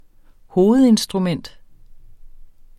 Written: hovedinstrument

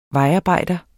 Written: vejarbejder

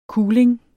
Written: kuling

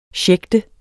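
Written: SCHÆCHTEDES

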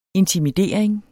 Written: intimidering

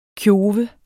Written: kjove

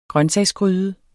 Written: grøntsagsgryde

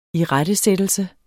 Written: irettesættelse

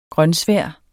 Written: grønsvær

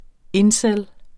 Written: incel

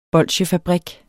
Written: BOLSJEFABRIK

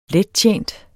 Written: lettjent

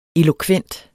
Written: elokvent

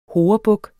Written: horebuk

